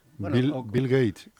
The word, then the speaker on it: Bill Gates: Ahora ya lo último que viene ya es el cambio en las en las comidas que quiere hacer el Big Wat, ese, el elemento ese. – ¿Quién? Big Wat, Gates. Bill, Gates, Bill Gates como se mm, pronuncia, dijo, es que quiere hacer comidas.